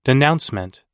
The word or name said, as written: denouncement